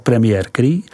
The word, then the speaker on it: Premier Cru: Premiere Crus, vyslov Premier Cru,